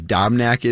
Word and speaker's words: Dobnak: You would need to, whoever that sixth guy is, whether it's Smeltzer or I believe Dobnak is how you say his last name, yeah.